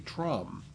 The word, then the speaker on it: Tram: Just in case you didn't know, Tram rhymes with mom, so that would be Trom.